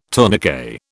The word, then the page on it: tourniquet: British English